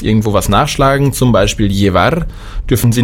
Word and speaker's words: llevar: Das Doppel-L wird ausgesprochen wie LJ, beziehungsweise nur wie J. Llevar heißt bringen oder tragen. Auf dem Festland wird es sehr oft ausgesprochen wie LJ. Allerdings hier, dadurch, dass wir viel Beziehungen zu Südamerika haben, sagt man einfach nur J. Also llevar.